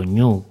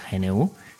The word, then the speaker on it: GNU: GNU, se puede pronunciar tanto ñu como por su sigla, GNU,